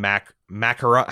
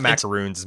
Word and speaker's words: macarons: macarons. How do you pronounce the fart? It's not macaroons. – Macarons, yeah. – Macarons. – Which is my, sure, that's my American pronunciation of a French word.